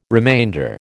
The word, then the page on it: remainder: American English